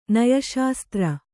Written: ನಯಶಾಸ್ತ್ರ

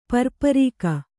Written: ಪರ್ಪರಿಕೆ

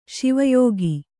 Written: ಶಿವಯೋಗ

